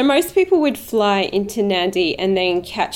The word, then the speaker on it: Nadi: Okay, so to get there, you fly to Nadi? – Yep, that's correct. – Okay, yep. which is spelt with only one N. There's no, it's N A D I.